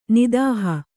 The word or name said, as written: ನಾತಹೂ